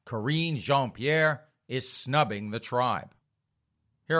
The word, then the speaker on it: Jean-Pierre: Corrine, now did we say her name was Jean-Pierre or, oh, it was Jean-Pierre because that sounded fancier. It sounded French.